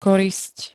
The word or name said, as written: korisť